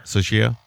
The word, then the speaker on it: Tsuchiya: Tsuchiya.